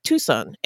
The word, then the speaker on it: Tucson: This place was called Chuxon by the native people who lived there. The Spanish pronounced it Tucson and went on to establish a mission there called San Agustin.